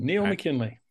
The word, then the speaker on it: Neil McKinlay: You did, Neil McKinlay.